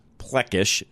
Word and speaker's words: Plechash: And his last name is spelled Plechash. It's pronounced Plechash for all of you listening.